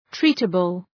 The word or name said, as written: treatable